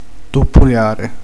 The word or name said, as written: bussare